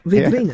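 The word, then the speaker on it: Védrine: Vedrine, yes.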